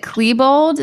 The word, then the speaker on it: Klebold: Dylan, is it Klebold? Is that how we say his name? – Yes, it is.